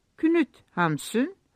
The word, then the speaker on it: Knut Hamsun: U harfini Ü olarak okur, O harfini de U diye telaffuz ederler. Bu arada J'ler Y olmuştur. Yani sizin Jan Garberek orada Jan Garberek'tir. Sonra Knut Hamsun, Knut Hamsun, Liv Ullmann da Liv Ullmann'dir.